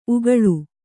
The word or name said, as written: ಉಕ್ಕಲು